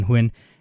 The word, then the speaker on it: Huynh: I'm joined here today by Calvin Huynh,